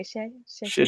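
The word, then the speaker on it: xie xie (谢谢): So hello was ni hao, and then thank you was, am I saying this correctly, xie xie? – Xie xie. Mm-hmm. – I heard it was say shit twice without the T.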